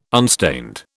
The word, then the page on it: unstained: British English